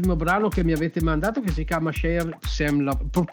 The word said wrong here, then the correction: Share Some Love: eh, scusate la mia pronuncia ma io faccio, faccio veramente cacare. e quindi, comunque Share Some Love.